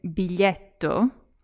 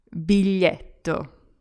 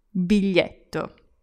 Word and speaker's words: biglietto: And a lot of people make the mistake of saying biglietto with the English word big at the beginning, so pronouncing the g. But in the middle, that kind of weird l sound, spelt with a gl, is kind of like a cross between a y and an l.